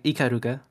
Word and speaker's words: Ikaruga: Oh, Ikagura? – Can you say her name again? Ikaruga. – Yeah, Ikaruga. Oh shit. I always thought her name was Ikagura, but yeah. Ikaruga.